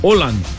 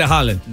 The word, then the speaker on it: Haaland: Todavía no lo estoy diciendo bien, pero se dice, en vez de decir la A, la doble A se convierte como en O. – Oh, wow. – Holland. Holland or Holland. Algo así. – Oh, wow. – Pero the point is que no se dice Haaland.